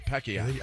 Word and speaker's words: Pacquiao: Pacquiao. – That's how they say it. Pacquiao.